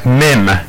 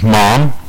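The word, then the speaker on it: Mim (מם): As you can see on the display behind us, we have Mim. And a lot of times it will also be pronounced as Mom, you know, in different areas, but you'll even see that Mem is right above verse 1 or 97 in our Psalms reading.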